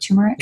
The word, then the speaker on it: turmeric: So I've been looking into, like, you know, turmeric. Am I say that right? Turmeric? – Yeah. Turmeric. Yeah. Yeah. That's correct.